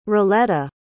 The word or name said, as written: rilletta